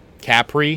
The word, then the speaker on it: Capri: Capri? – That's how they pronounce it. Capri. – Capri, excuse me. – Doesn't sound right, I know.